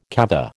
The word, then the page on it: kava: American English